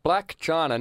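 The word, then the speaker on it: Blac Chyna: Black China. Now, can we just say Black China, that's spelled B-L-A-C, space, capital C-H-Y-N-A.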